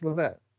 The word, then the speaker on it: Levesque: Lex Levesque, We say his name wrong, I think. – Lex Lovett. – Everyone says love it. – Love it? Lex yeah. Love it? Okay, well, whatever. I like Lovett better.